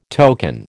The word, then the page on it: token: American English